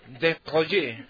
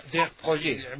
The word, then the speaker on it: Desproges: Desproges. – Proges. – Pierre Desproges.